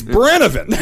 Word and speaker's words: Brennivín: Is he saying that right? – The name of the Brennivín. Mm-hmm. Yeah, that's it. – Brennivín. I nailed it. – Brennivín. – Yeah. – It's Brennivín.